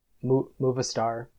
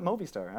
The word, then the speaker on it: Movistar: So it's just, that's, Yeah, I've always called it Movistar. I don't know. – I mean, that's how it's pronounced. – There's no E, so it makes sense to me.